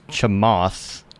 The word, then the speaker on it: Chamath: And there is, I believe his name is pronounced Chamath, C-H-A-M-A-T-H. Looks like Chamath.